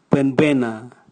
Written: bwenbwena

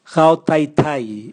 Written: gao taitai

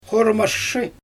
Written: xoramaʃʃɯj

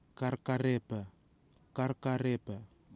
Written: KARKAREPA

